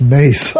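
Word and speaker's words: Myss: I'm not sure, but it's M-Y-S-S. – It's not mice. It might be pronounced mace. I'm not sure. I just remember that I've heard it pronounced by people who knew, and it's different than it looks.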